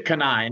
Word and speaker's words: Cannae: Now, we were just chatting, and how do you pronounce that again? And where does it come from? – We pronounce it Cannae, and it's actually an ancient fishing village located in Italy.